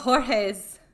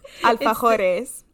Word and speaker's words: alfajores: What about, like, alfajores? – Oh. – Did I say it wrong? – Yes. – Alfajores? – Please stop. You're murdering me. – How do you say it? – Alfajores. – Oh, alfajores.